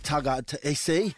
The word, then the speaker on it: Tagataese: We've got Matty Lodge, Gemma Shibasaki, Katoni Staggs, and Sam Tagaisi. That's how you say it.